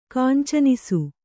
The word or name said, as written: ಕಾಂಚನಿಸು